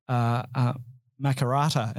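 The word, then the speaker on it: Makarrata: Makarata. Am I pronouncing that right? – Yeah, you're pronouncing it right.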